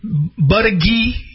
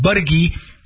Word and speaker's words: Buttigieg: Buttigieg, that's the way they say his name, Buttigieg, he's now superseding.